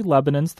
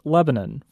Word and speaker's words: Lebanon: The search result came back with Lebanon, Oregon, and that led him to discover the more than 40 Lebanons throughout the United States. And a quick note about pronunciation. Bukaram says most of these cities in the U.S. are pronounced Lebanon versus the country Lebanon.